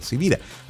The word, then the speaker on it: Sivira: Mi nombre es Carlos Javier Sivira y como estoy claro que mi apellido no es tan común, se escribe con S de semana y B de Victoria, Carlos Javier Sivira.